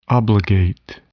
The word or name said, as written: obligate